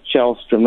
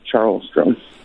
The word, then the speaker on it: Chahlstrom: There's no R in Chahlstrom. – No, there is not. – When did they take that out? – People have been pronouncing it forever, but it's Chahlstrom, not Charlstrom.